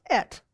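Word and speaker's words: et (את): And there's no way to really phonetically do this either, because when you try to linguistically look at it, what Aleph and Tav, it's pronounced et.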